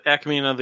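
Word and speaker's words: Ackmena: Arthur as Akmina. Saying that right? – Akmina, the bartender at the Mos Eisley Cantina.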